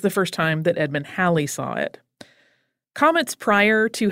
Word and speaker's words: Halley: Edmund Halley, you will also sometimes hear it pronounced Holly, sometimes Haley, but Haley is generally considered wrong, and whether Halley or Holly is correct is a matter of some debate. I'm going with Halley.